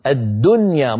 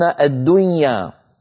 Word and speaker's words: الدنيا: يعني العرب تقول الدنيا، الدنيا مظهرة النون، ولا يقولون الدنيا، مع أنه قد اجتمع في النطق نون ساكنة بعدها ياء.